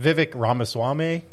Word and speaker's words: Vivek Ramaswamy: So that that Vivek Ramaswamy, yes.